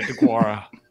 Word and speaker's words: Deguara: Josiah DeGuara. – Yeah, DeGuara.